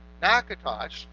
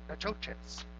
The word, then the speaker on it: Natchitoches: Now, the wife was advocating the pronunciation used in the dictionary. Natchitoches is the correct pronunciation. And the husband was advocating the pronunciation we hear often in the South, Natchitoches.